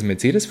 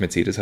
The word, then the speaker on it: Mercedes: Übrigens wollte ich jetzt nur noch sagen, Unterberg ist wieder was anderes, ähm, aber das Mercedes Formel 1 Team wenn man das auf Englisch ausspricht, grundsätzlich Mercedes hat das drei E's, die alle drei verschieden ausgesprochen werden. Mercedes. – Mercedes. – Mercedes, ja.